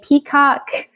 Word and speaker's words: Peacock: And I'm on Instagram at Deshae Peacock. And it's spelled D-E-S-H-A and then Peacock like the bird.